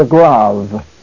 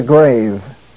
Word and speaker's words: grave: In some language classes, this is pronounced the grave. But in Greek, it is the grave.